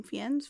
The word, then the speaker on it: Fiennes: Fiennes, Fiennes.